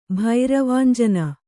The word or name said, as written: ಭೈರವಾಂಜನ